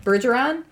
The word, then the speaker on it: Bergeron: This is Harrison Berger Bergeron Bergeron.